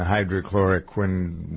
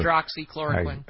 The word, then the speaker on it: hydroxychloroquine: It's a $20 dosage fee for the combination of the Z-Pak and the hydrochloroquine, whatever. – Hydroxychloroquine.